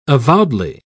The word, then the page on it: avowedly: British English